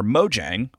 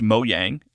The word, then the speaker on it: Mojang: Minecraft developer Mojang, which I learned is pronounced Mojang in Sweden, where the studio is based, and then in America it is pronounced Mojang.